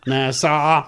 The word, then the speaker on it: Nassau: Nassau. – That's how you say it in North Carolina. Nassau.